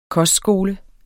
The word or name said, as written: kostskole